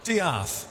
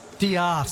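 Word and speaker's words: D'eath: No, no, you try it and I'll tell you whether you're right or wrong. – It is called Diaeth Takes a Holiday. I was wrong. – Diaeth. – Diaeth. – Isn't that much nicer? – Yeah, it's spelled death with a apostrophe in it.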